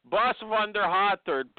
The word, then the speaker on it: Bas van de Haterd: How do you say it? – Close enough. Bas van der Haterd. – Bas van der Haterd.